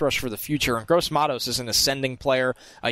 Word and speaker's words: Gross-Matos: Tell me how to say his name, finally. – Yeah, it's Gross-Matos.